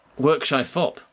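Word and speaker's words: Wyrkshifop: Workshifop. Okay, these are jokes. Okay, so I've got to work out... This is being written phonetically. It's like W-Y-R-K, work, shy, S-H-I, fop.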